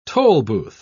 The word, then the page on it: tolbooth: British English